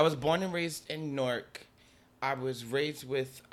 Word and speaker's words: Newark: I like how that's one syllable, by the way. Newark. – Yeah, it's Newark. – I'm used to Newark. – Like most people like Newark, but it's Newark. Like, no, it's Newark. Newark.